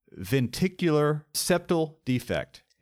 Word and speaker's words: ventricular septal defect: So talk to us a little bit about VSD, ventricular septal defect.